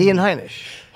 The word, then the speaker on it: Ian Heinisch: No, that's that you put too many syllables in. Ian! It's Ian! – Ian Heinisch.